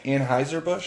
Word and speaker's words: Anheuser-Busch: Anheuser-Busch? – Yeah. – Okay. A man sued Anheuser-Busch for showing that hot women become attracted to normal guys. – What? – In 1991, Richard Overton sued Anheuser-Busch, makers of beer like Budweiser and Bud Light, due to what he interpreted as false advertising.